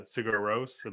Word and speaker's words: Sigur Rós: Uh Sigur Rós.